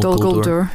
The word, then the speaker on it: Dol Guldur: Well, there is this this introduction of, of the necromancer and this uh, this dark, shadowy presence, it's Dol Guldur. Uh, am I pronouncing that right? – Yeah, Dol, Dol Guldur.